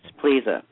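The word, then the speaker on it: Plesa: It's my lucky day, Edward Plessa. – Eddie Pleza. It's pronounced Pleza.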